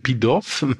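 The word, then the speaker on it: Pitof: muss man, Pitoff, muss man muss Pitoff. Äh, französisch ausgesprochen werden, Pitoff oder so. Ist ja Franzose.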